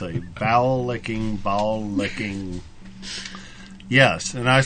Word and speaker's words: bowlicken: And it's really a bowel licking. I don't know if that's how they pronounce it. – A bowel licking. – B-O-W-L-I-C-K-E-N. – Oh, okay. B-O-W.